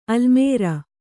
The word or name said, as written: ಆಲಮರು